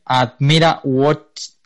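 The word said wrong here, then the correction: Admira Wacker: Admira Walker, Admira Walker. – Admira Walker, ese, de Austria,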